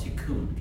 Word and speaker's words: tikkun: That process of repairing or finishing the creation is called tikkun. Can you say tikkun? – Tikkun. – I spell it T-I-K-K-U-N.